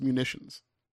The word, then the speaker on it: munitions: So that's pronounced munitions. – What did I say? – Mutations. – Oh, well, that definitely changes the meaning of the word then, doesn't it? – We changed the word by spelling it. – We changed the word by pronouncing it correctly.